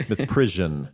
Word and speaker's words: misprision: I did have to go to Google Translate to make sure I pronounced it correctly. It's misprision. Misprision.